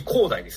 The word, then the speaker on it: 光大: 黒崎光大ですね。 – 光大、これで光大って読むんだ。